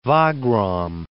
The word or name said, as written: Wagram